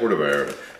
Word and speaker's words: Puerto Vallarta: Puerto Vallarta. – Yeah, Puerto Vallarta.